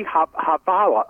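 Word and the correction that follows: Jhabvala: I always pronounce Javala. – Her name wrong. – Yeah. – Javala, okay.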